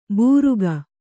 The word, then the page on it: ಬುರುಕ: kannada